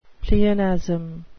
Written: pleonasm

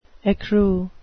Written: ecru

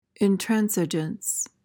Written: intransigence

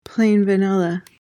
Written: plain-vanilla

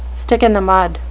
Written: stick-in-the-mud

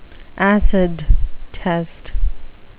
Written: acid test

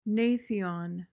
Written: gnathion

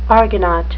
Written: argonaut

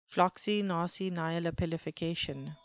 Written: floccinaucinihilipilification

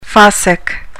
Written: fossick

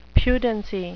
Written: pudency

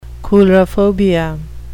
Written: coulrophobia

